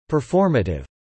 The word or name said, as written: performative